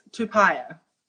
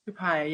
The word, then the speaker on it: Tupaea: Yes, Tupaia. – Tupaia. – Tupaia, yeah. T-U-P-A-E-A as pie, so Tupaia.